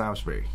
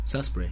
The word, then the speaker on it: Salisbury: 咁其实个读音即系个即系发音应该系 Salisbury。 – Salisbury 啊，咁所以而家叫佢梳士巴里道，系咪啊？